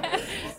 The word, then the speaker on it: Versace: This is not only utterly ridiculous, I am extremely offended that Versace, I can't pronounce Versace. – It. Versace.